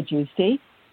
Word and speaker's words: Giusti: Welcome to the show, Debra Gusti. – Thank you. So great to be here. – Good. And I just have to check. Am I saying your name properly? – You are. Totally correct.